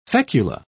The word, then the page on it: fecula: British English